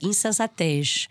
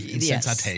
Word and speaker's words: Insensatez: So we're doing Insensatez. Am I butchering that? – No, not bad. Insensatez. – Insensatez. – How Insensitive by Antonio Carlos Jobim. – Okay. How Insensitive. Insensatez.